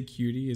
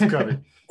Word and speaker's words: Cudi: Did I say cutie? Is it Cudi? – Yeah. – You said cutie. – Sorry. – It's Cudi. – That's this guy. I'm sorry, everybody. All the Cudi fans.